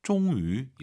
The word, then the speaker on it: 终于: 终于 위 하지 마시고 와유 발음 입술 동그랗게 말아서 终于 이렇게 발음하시기 바랍니다.